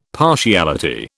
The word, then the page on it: partiality: British English